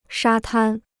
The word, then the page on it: 沙滩: shā tān